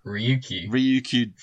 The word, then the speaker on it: Ryukyu: Is it Ryukyu? – Ryukyu. From Okinawa.